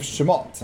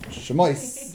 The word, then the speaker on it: Shemot: So it's the book of Shemot and not Shemois.